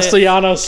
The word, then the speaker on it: Castellanos: As there's a drive deep into the left field by Castellanos, is that how you say it? – Castellanos.